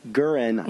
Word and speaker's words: Guerin: Gurin. Mm-hmm. I think I'm saying that right. – G-U-E-R-I-N.